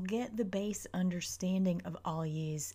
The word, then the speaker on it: Algiz: I know people pronounce it all kinds of different ways. Bear with me. This is the way it comes correctly out of my mouth, but you really should go with the pronunciation of it that works for you. So, Algiz.